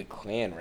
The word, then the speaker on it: Klan: You know, black men would rather go to a clown rally than... – A clown rally? Please clarify. – Okay, I'm sorry. I have a hard time pronunciating things. Black men would rather go to a Klan rally than on a date with Ronald.